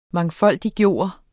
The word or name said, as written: MANGFOLDIGGJORTE